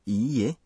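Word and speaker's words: Iie (いいえ): Na palavra iie, a pronúncia da vogal i é prolongada.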